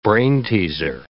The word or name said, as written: brainteaser